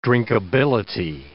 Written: drinkability

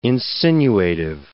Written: insinuative

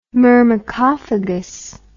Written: myrmecophagous

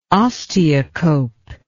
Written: osteocope

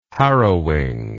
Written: harrowing

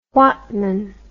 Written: whatman

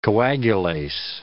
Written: coagulase

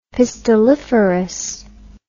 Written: pistilliferous

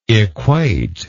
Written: equatable

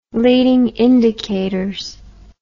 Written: leading indicators

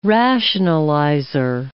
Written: rationalizer

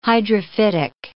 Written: hydrophytic

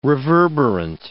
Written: reverberant

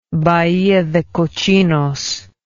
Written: Bahia de Cochinos

